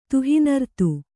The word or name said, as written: ತುಹಿನರ್ತು